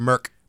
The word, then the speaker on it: Merck: Merck.